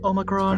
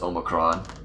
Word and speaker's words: Omicron: Omicron. – It's pronounced Omicron. – Yep. Omicron. You have to say Omicron like this.